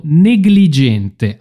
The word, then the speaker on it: negligente: negligente. Attenzione a questa parola, negligente. Non è negligente perché c'è GLI, ma è una delle poche parole che effettivamente si pronuncia gli.